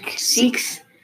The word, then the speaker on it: Sikhs: I think it reminds the Sikhs or something? How do you say it? – Sikhs.